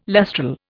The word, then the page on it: lustral: Indian